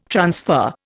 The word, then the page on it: transfer: Indian